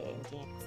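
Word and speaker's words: nginx: リンクスと書いて、エンジン X と読むサーバーのあれを C++ で作ったらめっちゃいけてるよねっていう課題です。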